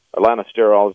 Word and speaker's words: lanosterol: Lanosterol is the correct pronunciation.